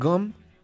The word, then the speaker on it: Ghum: Vamos a escuchar y a ver entonces este video de Bad Brain, Son Gum, directamente desde Londres, aquí en el White Branch. Ahí escuchamos a Gum. Se escribe G-H-U-M.